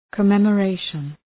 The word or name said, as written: commemoration